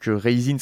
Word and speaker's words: Kheyzine: Vous faites ce que vous voulez, mais si vous lui lâchez un petit billet, ça lui fera pas de mal, n'est-ce pas Donc, Raisin, ça s'écrit K-H-E-Y. Z e I-N-E. K-H-E-Y-Z-I-N-E. C'est pour ça qu'on le prononce un peu euh, avec le R, euh, comme dans ma rapta.